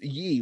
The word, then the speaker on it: Yi: It is Y-I-Ye. It's not Y-E-Ye. Like ye old, ye old.